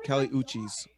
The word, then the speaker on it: Uchis: It's either like that or like a hungry mouse, like Cali Uchis. Oh, cheese. Oh, Uchis. – I'm just going to say Cali Uchis.